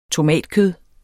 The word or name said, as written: tomatkød